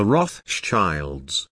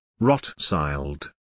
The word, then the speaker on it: Rothschild: Rothschild. Commonly the last syllable is pronounced as if it were child, it is Schild, Shield.